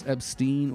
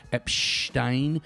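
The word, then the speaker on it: Epshteyn: and it's pronounced with the SH sound in the middle. It's not Boris Epstein or Epstein. It's Epstein.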